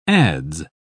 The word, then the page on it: adz: British English